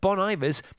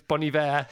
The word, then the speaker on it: Bon Iver: Bon, well, it's spelled Bon Iver. There's a place called Iver off the A40, and it's spelled like that. And Bon is a separate word. So I'm going for Bon Iver. It doesn't work.